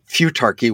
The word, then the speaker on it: futarchy: futurarchy. Futurarchy is that is that how you pronounce it? – Futarchy would be a sort of fancy name for decision markets applied to government.